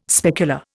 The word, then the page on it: specular: Indian